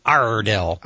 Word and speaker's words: Iredell: One of my best friends is from there, and he says, say it like a pirate, buddy, Iredell.